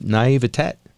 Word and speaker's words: naivete: just naive naivete. Did I pronounce that right, Joe? You're a teacher. – Uh, I'm not an English teacher, but I think that's pretty close.